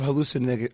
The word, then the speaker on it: hallucinogenic: Like, what kind of hallucinogenic, how do you say that word again? – Hallucinogenic?